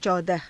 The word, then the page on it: caudaha: hindi